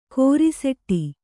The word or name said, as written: ಕೊರಸಾಟ